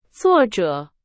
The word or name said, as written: 作者